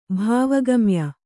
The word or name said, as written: ಭಾವಗಮ್ಯ